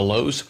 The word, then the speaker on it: Louhs: You know, friends, we are on Facebook, Instagram, Twitter, and YouTube, all under the headings of The Lowes. And that last name is spelled L-O-U-H-S. It's The Lowes.